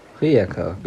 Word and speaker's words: Rijeka: Rijeka, genau.